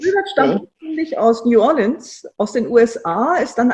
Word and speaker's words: New Orleans: Wilbert stammt ursprünglich aus New Orleans. Ich glaube, ich muss das anders aussprechen. Wie heißt das? – Nee, das war richtig.